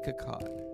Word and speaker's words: Akkad: Akut following the release of Ends. What is his name? Let me say it properly. Malek Akkad.